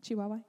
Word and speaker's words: Chihuahua: Chihuahua, Mexico. Am I saying that right? – Chihuahua, Mexico. Okay. I was making sure.